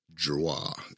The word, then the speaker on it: Giroir: Giroir is how I pronounce it. I don't know how you really pronounce it. It's G-I-R-O-I-R. I think it's French, Giroir. Can you say that?